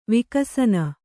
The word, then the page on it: ವಿಕಸನ: vikasana